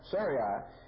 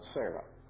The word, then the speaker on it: Sarai: Now, Sarai, and that's how you pronounce her name here. It's not Sarah. It changes to Sarah later. God changes it. But here it means it's Sarai.